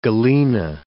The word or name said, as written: galena